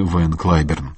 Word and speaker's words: Ван Клиберн: В среду в Соединенных Штатах скончался выдающийся американский пианист Ван Клиберн, как его называли в России. Правильный вариант произношения его имени – Ван Клиберн.